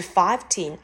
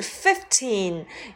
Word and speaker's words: fifteen: 十五不是 fifteen，而是 fifteen，由 f i f fifth 加上 teen 构成。